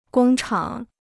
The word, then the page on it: 工厂: gōng chǎng